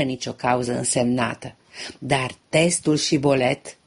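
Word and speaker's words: Shibboleth: Evreii din Galaad îi puneau pe cei din tribul lui Efraim să pronunțe Shibolet. Dacă aceștia spuneau Sibolet cu S, erau identificați drept dușmani și uciși.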